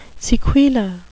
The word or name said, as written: sequela